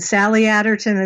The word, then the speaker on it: Sally Aderton: And Sally Aderton is spelled S A L L Y A D E R T O N.